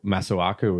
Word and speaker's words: Masuaku: another one to highlight, Miles, I thought Masuaku was good. – Masua looked really good. – Now, the reason I said his name before throwing to you is just so you knew how to pronounce it.